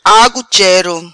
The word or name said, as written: Agu chero